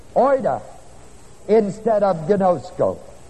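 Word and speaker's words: gnosko (γινώσκω): It is not the Greek word for know, K-N-O-W, which is the word gnosko. Gnosko is spelled G-I-N-O-S-K-O.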